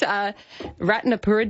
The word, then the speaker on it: Ratnapura: They hail from the south of Sri Lanka, in the, I'm going to probably butcher this, Ratnapura district.